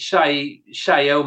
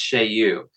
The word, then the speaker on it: Chaillot: I think it's Chaillou may be the correct pronunciation. Google told me it was pronounced Chaillou. It's spelled Chaillot.